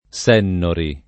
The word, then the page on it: Sennori: lingua italiana